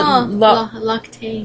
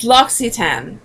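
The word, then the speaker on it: L'Occitane: You called it L'Octane. – L'Octane. But it's L'Occitan.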